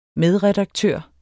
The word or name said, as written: medredaktør